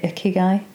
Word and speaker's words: Ikigai: And we did something called, how do you pronounce it, Ikigai? – That's good enough, yes.